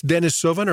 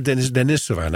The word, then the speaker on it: Denisovan: How do you pronounce it? – It's Denisovan or Denisovan. I've heard both.